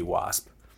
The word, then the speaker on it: W4SP: W4SP. Is that pronounced WASP? Is it W4SP? Tell me your thoughts. – Oh, if you're going to pronounce P0NED as pwned, or P0WNED as pwned, then I think WASP should be WASP.